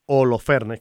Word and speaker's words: Holofernes: Judith y Holofernes, o Holofernes, porque ah, es con H, eh, y, hay quienes la pronuncian como una J, otros pues Holofernes, que era el, general a cargo del ejército este de, del rey asirio, que quiere mm, invadir y, tomar posesión.